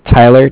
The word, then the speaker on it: Tyler: My name is Tyler, T-Y-L-E-R, and I'm calling from Bank of Cardiff. It's spelled C-A-R-D-I-F-F, as in Frank.